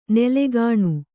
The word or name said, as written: ನೆಲೆಗಾಣು